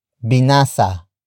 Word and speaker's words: binasa: Ano ang binasa niya? Ano ang binasa niya? Ano ang binasa niya? It means, what did he or she read? Word for word it's, ano, ang, binasa, niya.